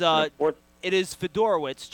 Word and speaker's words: Fedorowicz: Federici, the guy from Iowa. – It is Fedorowicz, just FYI.